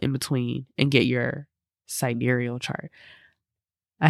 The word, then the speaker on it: sidereal: You can pull your tropical birth chart and your sidereal chart. Did I say it right? Yes.